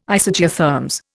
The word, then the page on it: Isogeotherms: Indian